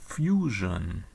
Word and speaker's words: Fusion: The festival name is often depicted in Cyrillic letters as Fusion, but pronounced like the English word fusion.